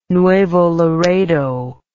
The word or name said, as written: Nuevo Laredo